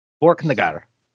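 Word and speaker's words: Borknagar: Have I been saying that correctly? Borkenegard. – That's all good.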